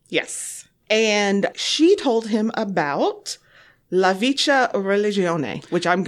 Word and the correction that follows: La Vecchia Religione: La Vecchia Religione.